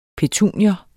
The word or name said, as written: PETUNIER